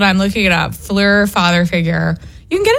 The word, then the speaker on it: PHLUR: Are you sure it's not Freud? It's not pronounced Freud? – No, it's Fleur.